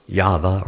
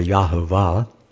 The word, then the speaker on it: Yahweh: So the Tetragrammaton and proper Antioch Hebrew scrolls spells YHVH, pronounced Yahweh or Yahuwah.